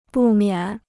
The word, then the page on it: 不免: bù miǎn